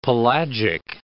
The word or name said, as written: pelagic